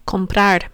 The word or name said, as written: comprar